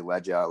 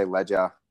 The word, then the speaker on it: Ledger: And my last name, for example, obviously Ledger, like if I'm registering for a tournament, if I'm saying it to anyone, they're like, what's your last name? I say Ledger.